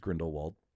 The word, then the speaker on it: Grindelwald: They were like, this is too much heat for us to handle with Fantastic Beasts and you being cast as Grindelwald.